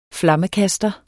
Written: flammekaster

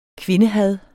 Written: kvindehad

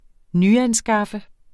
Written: nyanskaffe

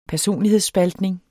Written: personlighedsspaltning

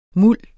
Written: muld

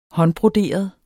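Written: håndbroderet

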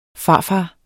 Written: farfar

